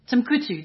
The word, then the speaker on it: Timbuktu: Timbukutu. – I just want you to say that again so here if you're going to bastardize it. – Wow. Timbuktu. How do you say it? Timbuktu. That's it.